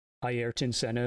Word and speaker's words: Ayrton Senna: Ayrton Senna, or Ayrton Senna, if you want to pronounce it properly, was one of Formula One's greatest drivers.